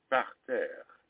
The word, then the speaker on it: parterre: Well, the problem with that word, and I got to be careful here, is that you have to roll your R's just slightly in two places. It's difficult to say. It's parterre. – Parterre.